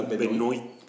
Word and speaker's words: Benoist: Es Benoit. – En, francés debería ser Benoit, pero creo que ella lo, lo pronuncia Benoist. Pues Benoist. Porque, es gringa.